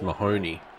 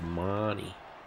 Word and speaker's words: Mahoney: Oh, I don't know the origins of it, but if you used his name in America, it would be pronounced Mahoney, like from Police Academy. – But, yeah, that was a good show. – But in Australia, it's Marnie.